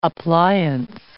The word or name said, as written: appliance